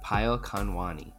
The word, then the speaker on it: Payal Khanwani: Payal Khanwani.